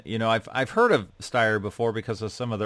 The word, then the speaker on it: Steyr: Steyr.